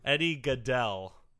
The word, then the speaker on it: Eddie Gaedel: Eddie Gaedel.